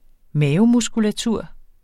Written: mavemuskulatur

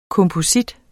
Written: komposit

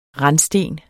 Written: randsten

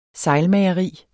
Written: sejlmageri

